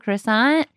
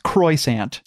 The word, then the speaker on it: croissant: Like, I feel like anytime I'm eating a chocolate croissant, I'm getting a special little good luck fortune. – Well, okay. Now, it is pronounced croissant.